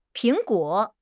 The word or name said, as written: Ping2 guo3